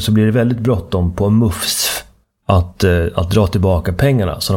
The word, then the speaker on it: MUCF: Och det här resulterat då i att Myndigheten för ungdoms- och civilsamhällesfrågor, MUFS, M-U-C-F, det skrivs ofta i tidningsartiklarna med små bokstäver, vilket antyder att man ska uttala det MUFS,